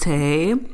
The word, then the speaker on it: Tay: My name is Tay. My name rhymes with everything.